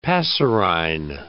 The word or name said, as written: passerine